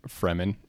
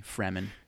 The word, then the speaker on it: Fremen: Freeman with one E. Fremen. – Got it. – That's not how you spell it, but that's how you remember it.